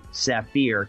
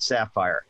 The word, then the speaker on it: Saphir: I was going to just say Saphir because it looks like it's spelled that way. But you say the people into whose family you've married, they're the ones that have had the name for generations. They pronounce it Sapphire.